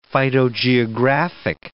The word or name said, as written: phytogeographic